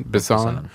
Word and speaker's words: Besson: And I swear to God, it looks like the director, was it Luke, how do you pronounce his last name? Besson or Besson? Besson.